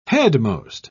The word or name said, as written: headmost